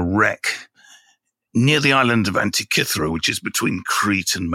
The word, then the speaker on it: Antikythera: Firstly, let me say, it's the Antikythera mechanism. It's not the Antikythera mechanism. That's how they pronounce it in the film, you know. They spent $295 million making it, and they couldn't bother to ask a Greek person how to pronounce it. It's pronounced Antikythera.